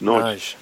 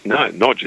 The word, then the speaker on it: Nagy: And my surname is spelled N-A-G-Y, which is Nagy or Nagy or something. And Hungarian is pronounced Nodge. – Nodge. – Nodge. Like N-O-D-G-E. That's what it would be.